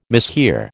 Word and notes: mishear: American English

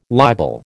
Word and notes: libel: American English

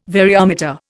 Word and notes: variometer: Indian

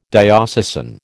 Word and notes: diocesan: American English

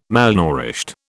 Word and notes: malnourished: British English

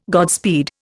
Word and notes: Godspeed: Indian

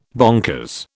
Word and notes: bonkers: British English

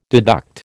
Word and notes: deduct: American English

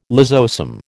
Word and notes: lysosome: American English

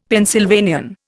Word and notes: Pennsylvanian: Indian

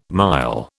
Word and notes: mile: British English

